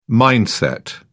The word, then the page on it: mindset: British English